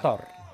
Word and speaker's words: Qatar: Qatar. – That's it. That's the proper pronunciation. One more time. One more time. – Qatar. – Qatar. – Oh, it's tough, though. That rolling R is tough.